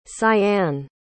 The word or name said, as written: cyanne